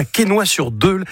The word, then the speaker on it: Quesnoy-sur-Deule: Tu habites à Quesnoy-sur-Deule. Est-ce que je l'ai bien prononcé? J'ai pas écorché le nom? – Impeccable.